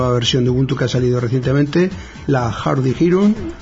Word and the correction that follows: Hardy Heron: Hardy Hero, ¿cómo se pronuncia? – No sé, nunca me acuerdo el nombre, ¿cómo es? – Hardy Hero. – Sí, creo que es la garza vamos. Era una cosa así, la garza dura o no sé, es que tiene una traducción muy así. Mejor es como tú lo pronuncias. A ver, vamos a escucharlo otra vez. – Hardy Heron.